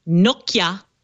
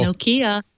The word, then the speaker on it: Nokia: I was also told Withings, and I will tell you, David, I got the smackdown from Nokia. It's not Nokia. – Every time we say it, we get people from Norway or Finland or whatever is calling and saying, you're not saying it right.